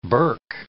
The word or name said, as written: burke